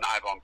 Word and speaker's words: Eibon: There's two guys, actually, who run Ibn Press. And that's spelled E-I-B-O-N, if anybody doesn't know. E-I-B-O-N, Ibn Press.